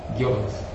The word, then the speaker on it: Goebbels: How do you say his name? – Goebbels. – Goebbels. Oh, okay.